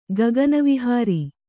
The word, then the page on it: ಗಗನವಿಹಾರಿ: kannada